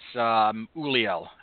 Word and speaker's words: Ulliel: There's a weird, yeah, the D is silent. He's French, and it's Ulliel, I believe.